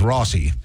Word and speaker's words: Rossi: And it's just highly unfortunate that Bossy rhymes with Rossi.